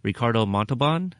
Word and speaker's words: Ricardo Montalban: both portrayed by Benedict and also Ricardo Montalban.